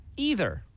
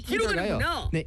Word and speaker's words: either: 근데 이 either가요. 네. Either. either라고도 발음하는 사람들 있습니다. 네. – 아, 어, either 약간. – 네, either. 아이돌이 아니고 either. – Either. – Right? 네. It's your choice. Missile, missile, right? It doesn't matter. It's your choice, right?